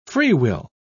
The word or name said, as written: freewill